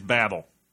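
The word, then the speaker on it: Babel: And the thing about this movie is, like, usually your crashes or your babels, they're all about how people who don't know each other... – It's babble. – Your babsels. – Yeah, you're going to pronounce it wrong. It's babble, I think.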